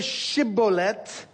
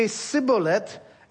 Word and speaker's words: Shibboleth: The word is Shibboleth. Ephraim doesn't have the sh sound, so they say Shibboleth.